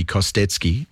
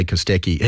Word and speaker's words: Kostecki: Brody Kostecki, and, you know, Spiro's in the studio with me, so I feel like we should call him Brody Kostecki. We should say it properly. Hello, Spiro. – Hello. Good afternoon. How are you? – Yeah, I'm good, mate. I'm good. So Brody Kostecki, which we say Kostecki, he's on pole at the moment.